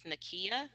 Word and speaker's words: Nakeya: My first name is Nakia, and it's spelled N-A-K-E-Y-A. Last name is Gore, G-O-R-E.